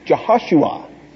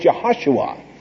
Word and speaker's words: Jehoshua: Jehoshua is really the way it is pronounced, Jehoshua.